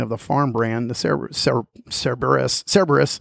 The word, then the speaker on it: Cerberus: Yeah, how do you say it? Cerberus? – Cerberus.